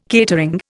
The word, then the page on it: catering: Indian